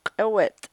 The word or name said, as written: k'ewet'